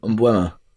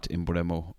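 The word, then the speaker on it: Mbeumo: Umbuema. I can tell you, it is not Imbremo or John Duran.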